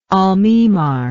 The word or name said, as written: almemar